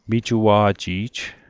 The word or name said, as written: mijua'ji'j